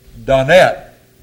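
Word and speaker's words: Donne: There was a poet in the 17th century by the name of Donnet. That's the way you pronounce it, incidentally. I checked it.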